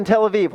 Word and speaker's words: Tel Aviv: Tel Aviv? All right. I looked for a church in Tel Aviv.